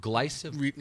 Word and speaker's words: glyphosate: Is it glyphosate? – You know, like consumer. – I think I pronounced glyphosate. – Glyphosate. Yeah, I think you said right.